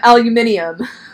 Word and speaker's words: aluminium: He pronounces aluminum aluminium.